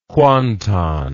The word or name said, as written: kuantan